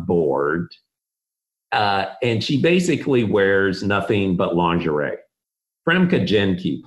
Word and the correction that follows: Famke Janssen: played it. Is that the way you say her name? – Famke Janssen, the perfect mate.